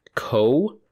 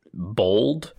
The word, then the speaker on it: kobold: And according to Wowpedia, kobold is pronounced co-bald. – Kobold. – Bald. – Bald. – Now, bald. B-A-W-L-D is how they're spelling that second syllable there. Now, I think both of us pronounce it bold.